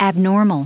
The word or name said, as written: abnormal